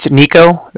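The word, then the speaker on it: Niko: It's Nico. That's N-I-K-O.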